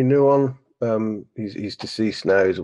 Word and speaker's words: Nouwen: It's Nouwen. Yeah. It's spelled Henry, but it's Henri Nouwen.